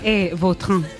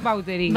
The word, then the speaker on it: Vautrin: Vautrin. Bueno. Pero aquí decimos Vautrin. – Claro, sí, sí. ¿No? Pues, es que si me pregunta exactamente cómo se pronuncia, pues así. Claro, pero sí. Yo ya soy Vautrin. – Ya. – Está españolizado total.